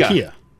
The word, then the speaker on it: IKEA: IKEA.